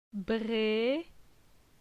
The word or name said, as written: Bre